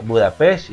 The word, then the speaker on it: Budapest: No, es que se pronuncia Budapest.